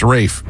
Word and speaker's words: Ralph: Pronounced Rafe, even though Rafe, it's spelled Ralph.